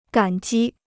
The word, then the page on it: 感激: gǎn jī